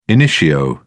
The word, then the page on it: initio: British English